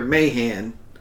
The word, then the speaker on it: Mahan: But the doctrine they're still working from, though, is this classic Alfred Mayer I'm getting his name wrong. Alfred Thayer Mahan, right.